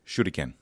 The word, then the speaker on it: shuriken: Yeah, Japanese words typically don't have an emphasis on their syllables, so we go shuriken, so we put that emphasis on the shur.